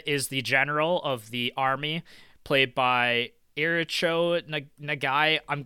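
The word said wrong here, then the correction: Ichirō Nagai: Ichiro Nagai.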